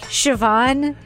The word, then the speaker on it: Siobhan: It's one of those names where, like, if you don't know, like, Greek mythology or you don't know how it's spelled, it's like Siobhan. – Oh, Siobhan.